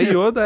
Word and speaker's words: Yoda: É Yoda mesmo? Eu falei certo? Pera aí. – É Yoda, é isso aí.